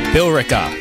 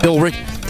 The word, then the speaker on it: Billerica: How do you pronounce that? Billerica. There's an E in there. – Billerica. – Billerica, maybe? – Billerica. – Oh, that makes sense. Just leave out the E.